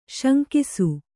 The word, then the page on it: ಶಂಕಿಸು: śankisu